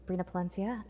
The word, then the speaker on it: Brina Palencia: All right, so we are here at the Florida Anime Experience with Brina Palencia. – Good job on the pronunciation. That's accurate. – Did I get it right? – Yes. – Oh, Brina Palencia.